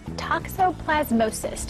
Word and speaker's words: toxoplasmosis: Pet talk this morning, toxoplasmosis, did I say it correctly? – Very good, very good.